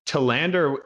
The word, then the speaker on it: Tellander: Well, actually, what's interesting is I've gotten Tellander my whole life. – Oh. – But Tellander is actually a more accurate representation of like the Swedish word. It's Swedish last name. So, you know, Tellander. If you think of the movie Girl with a Dragon Tattoo, her last name is Lisbeth Salander. – Oh. – And they have the emphasis on the and, you know, Salander, Tellander. So it would be accurate, but my whole life, you know, my dad and his dad, they all said Tellander.